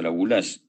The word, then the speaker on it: Laboulaye: Eh, Eduardo eh, La que en francés se pronuncia Laboulage precisamente,